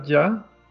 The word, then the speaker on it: Jah: Y es una entrevista con Moriba Ya, así, yo creo que así se pronuncia ya, J-A-H.